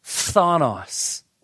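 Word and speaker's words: phthonos (φθόνος): I mean, you can use different words for envy, but the word he chose is, it's hard to pronounce, phthonos. Ready? 1, 2, 3. Phthonos. P-H-T-H. O-N-O-S, phthonos.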